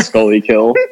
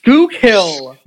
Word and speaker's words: Schuylkill: You have me big time tongue tied because you said Scully Kill. – Scully Kill. I don't know how to say it. – Sch- Schuylkill.